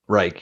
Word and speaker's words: Reich: how do you say it? Frank Reich? – Reich, yeah.